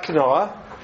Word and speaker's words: quinoa: where there's a discussion about quinoa, or quinoa, how is it pronounced? – Quinoa.